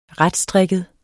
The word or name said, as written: retstrikket